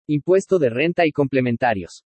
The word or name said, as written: IMPUESTO DE RENTA Y COMPLEMENTARIOS